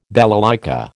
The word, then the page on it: balalaika: American English